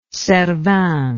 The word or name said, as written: cervin